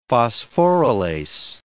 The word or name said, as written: phosphorylase